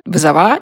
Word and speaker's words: вызова: а потом есть вызов, вызовы, вызова, их нужно так на медицинском сленге произносить. Почему-то именно такое произношение, да, закрепилось, по крайней мере, в Петербурге, где я работала.